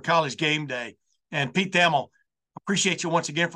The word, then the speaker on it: Thamel: And by the way, it is Thamel, man. His name gets pronounced a lot of different ways, but once again, I double-checked with him before we started. It is Pete Thamel.